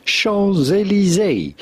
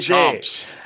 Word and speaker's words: Champs-Élysées: How to pronounce with the typical French pronunciation, Champs-Élysées. Champs-Élysées. – Champs-Élysées. – The avenue of the Champs-Élysées. Champs-Élysées. – It sounds like chomps. I chomp my Élysées. – No, Champs-Élysées.